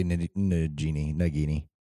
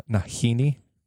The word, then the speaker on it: Nagini: It is actually Nagini. – Did I say it wrong? – No. I don't know. – Okay. Nagini. – Is the G silent? Nahini? – No, so, that's the stuff you put in, like, shawarma.